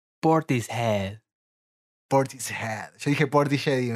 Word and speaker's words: Portishead: o convencidísima esta persona de que Portishead o Portishead, Portishead se pronuncia Portishead.